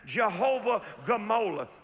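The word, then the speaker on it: Jehovah Gmolah: Say that one time. Jehovah Gamola. It's spelled Jehovah and then G-M-O-L-A-H. And it means God of recompense.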